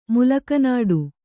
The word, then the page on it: ಮುಲಕನಾಡು: Kannada; mulaka nāḍu